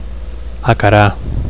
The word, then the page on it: acarar: Catalan